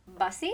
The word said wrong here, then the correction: Bassey: is that how you say it? – I have no idea, truly. – Okay, because I don't know that that's Mark E. Bassey, excuse me, Mark E. Bassey. – Oh, Bassey, yeah, Bassey.